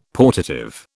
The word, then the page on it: portative: British English